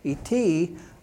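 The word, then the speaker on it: Et (את): Now, not et, not the space guy. Don't worry about that. I'm talking about the letter E and a T. Et is how you pronounce it.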